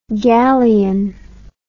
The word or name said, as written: gallein